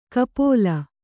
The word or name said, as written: ಕಪೋಲ